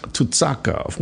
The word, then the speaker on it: Tucakov: Tucakov.